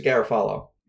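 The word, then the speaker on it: Garafolo: I think it's just Garofalo. – Garofalo. – Yeah. – Ian Rappaport. It's not Garofalo, like Jimmy Garoppolo.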